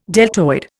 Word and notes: deltoid: Indian

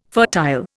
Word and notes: fertile: Indian

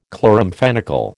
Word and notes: chloramphenicol: American English

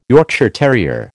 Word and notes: Yorkshire terrier: American English

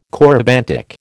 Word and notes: corybantic: American English

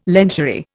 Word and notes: lingerie: Indian

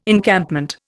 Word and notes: Encampment: Indian

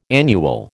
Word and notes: annual: American English

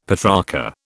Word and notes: Petrarcha: British English

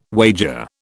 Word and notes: wager: British English

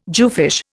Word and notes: jewfish: Indian

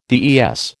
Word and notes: DES: American English

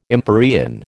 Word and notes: empyrean: American English

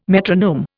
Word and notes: metronome: Indian

